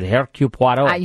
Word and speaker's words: Hercule Poirot: It's right. It's how you pronounce it. Hercule Poirot.